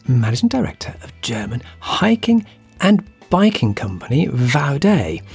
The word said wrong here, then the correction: Vaude: or, as you'll soon find out, how it should be pronounced, Vaudi.